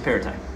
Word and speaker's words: Perrottet: I haven't heard his name pronounced yet. – Oh, yeah, it's Perrottet. I've heard it.